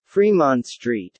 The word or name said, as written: Fremont Street